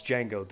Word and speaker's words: Django: "My name's Django. The D is silent."